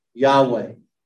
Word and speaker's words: Yahweh: So the personal Yahweh, and again, there are no vowels in Hebrew. So the way that Yahweh is spelled in the Hebrew is YHWH, Yahweh. We added the vowels because we need them.